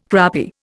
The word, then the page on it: crappy: Indian